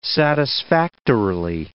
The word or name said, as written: satisfactorily